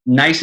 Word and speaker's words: gneiss: So yeah, nice is a homonym. So it obviously is nice. So use the word nice, but nice is spelled G-N-E-I-S-S, which is a rock type.